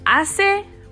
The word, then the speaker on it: hace: Don't forget, hace buen tiempo, that first word, hace, it is spelled with an H. However, the H is silent.